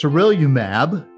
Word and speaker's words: sarilumab: So this drug is sarilumab, right? Did I say that right? – Yep, that's correct.